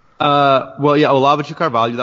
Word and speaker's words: Olavo de Carvalho: Olavo de Carvalho, that was like pretty good pronunciation.